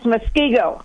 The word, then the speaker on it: Muskego: Okay, the first word is spelled M-U-S-K, as in Elon. – Yeah. – And it continues E-G-O, as in ego. And it's pronounced Muskego.